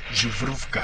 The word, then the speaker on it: Zubrovka: Me explicó una vez Sergio Pitol que tenía que pronunciar Zubrovka.